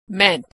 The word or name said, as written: meant